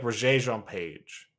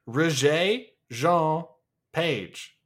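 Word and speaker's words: Regé-Jean Page: Jean, it's probably like Regé Jean Page. – It's two syllables, my man. I don't know what you want me to tell you. Regé Jean Page. Two syllables. Regé.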